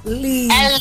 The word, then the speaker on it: Leeds: Come si pronuncia bene il tuo paese? – Leeds. – Leeds.